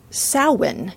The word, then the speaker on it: Samhain: is pronounced Samhain, which sort of sounds like summer's end.